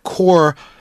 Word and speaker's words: corps: Core, C-O-R-P-S with a silent P, doesn't quite carry, I don't think in English, that same meaning.